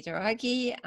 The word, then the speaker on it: Douraghy: My name is Katty Douraghy.